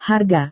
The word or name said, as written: harga